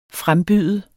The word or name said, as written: frembyde